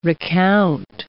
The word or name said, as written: recount